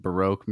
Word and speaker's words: Baroque: I just learned how to pronounce that last night. Baroque music. I was just like, barbecue? – Baroque?